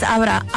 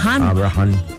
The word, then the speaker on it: Abraham: Es Abraham. – Abraham. Que tiene acento en la segunda H.